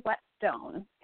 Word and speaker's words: Whetstone: So my last name is Whetstone, just pronounced like a wet stone.